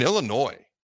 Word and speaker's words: Illinois: In Illinois, they're actually a big-time dog to Penn State. Can you look up the spread on Illinois where the S is silent versus Penn State this weekend?